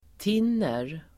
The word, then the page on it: tinner: [t'in:er]